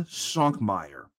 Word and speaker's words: Svankmajer: How do you pronounce that last name? – Jan Svankmajer. – Jan Svankmajer. – Svankmajer. Svankmajer.